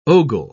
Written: ogle

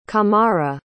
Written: camara

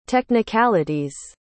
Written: technicalities

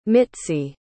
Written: mitzi

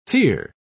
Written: fere

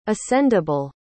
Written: ascendable

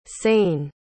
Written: sain

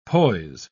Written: poise

